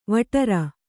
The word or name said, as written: ವಟ್ಟಾರ